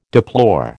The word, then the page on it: deplore: American English